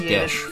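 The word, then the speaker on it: geis: To the point where I had to cast a Gaius on him so that... – It's actually pronounced Gesh, fun fact. – Gesh? – Yeah, Gesh. – Pronounced Gesh? – It's Gaelic.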